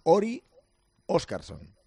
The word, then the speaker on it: Orri Oscarsson: Él dice que se llama Orri Oscarsson. Orri. Lo ha pronunciado el otro día porque le hemos preguntado, por favor, que nos dijese.